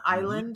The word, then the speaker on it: island: Now, here's the thing about when you say island, because it's pronounced just like the piece of land out in the ocean, island, mm-hmm. but it does not have the S. There is no right. S in island.